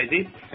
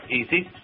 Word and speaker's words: ISIS: Tức là tôi thì đọc là ISIS, còn cô Mary thì đọc ISIS, thì cái nào cũng được, quý vị nghe quen miệng, quen tai mà thôi.